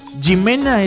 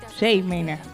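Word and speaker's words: J Mena: Me parece que se dice J-Mena ¿Cómo? Mena, no Jimena, J-Mena ok. J Balvin. – No, claro. – Como ah, claro, Jay Mena.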